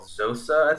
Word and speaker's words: Xhosa: Yeah, it's actually, the language is called Xhosa, I think is how you say it. X-H-O-S-A.